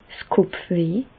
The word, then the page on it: 's Kopfweh: Bas Rhin; Reichshoffen